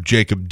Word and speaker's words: Jakub: And for whatever reason, they decided when he was born to spell his name J-A-K-U-B instead of J-A-K-O-B. But it's still pronounced Jacob, right?